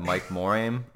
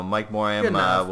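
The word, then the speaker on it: Mike Morhaime: Gonna botch this dude's name, but Mike Moraim. I'm pretty sure the H is silent. – That's gotta be close. – I don't know. Mike Moraim will be stepping down from his role as the president of Blizzard Entertainment after 27 years of service to the gaming community.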